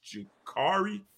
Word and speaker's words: Ja'Cory: But Ja'Cory is a guy, or it, you know, actually somebody corrected me. It's Ja'Cory.